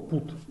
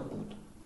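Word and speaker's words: put: А точно так же пишется слово пут, p u Это бат, а это пут.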